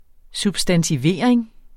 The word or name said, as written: substantivering